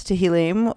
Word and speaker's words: Tehillim: So for our second segment, we're going to be discussing Psalms, Tehillim, or as I will call them throughout this segment, Tehillim, because I cannot muster the emphasis on the last syllable on a consistent basis.